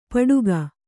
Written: ಪಟುಕ

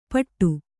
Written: ಪಟ್ಟು

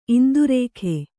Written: ಇಂದ್ರಕ

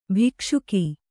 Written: ಭಕ್ಷಕಿ

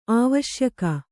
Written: ಅವಸಾಯಕ

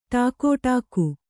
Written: ಠಾಕುಟೀಕು

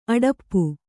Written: ಅಡಪ್ಪು

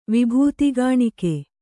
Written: ವಿಭೂತಿಗಾಣಿಕೆ

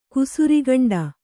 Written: ಕುಸುರಿಗಂಡ

